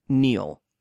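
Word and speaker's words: Kneale: The original writer, his name was Nigel, I'm assuming I'm saying this correct, Neil. It's one of those ones that has silent K's and shit in it.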